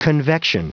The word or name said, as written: convection